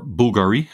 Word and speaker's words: Bulgari: It was Hublot, Zenith, and Bulgari, or Bulgari, as they pronounce it themselves.